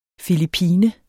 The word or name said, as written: filippiner